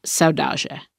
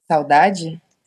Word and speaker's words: saudade: Saudade. – Saudade. Did I say that right? – Saudade. Mm-hmm. Saudade. It's pretty dry in the end, like saudade.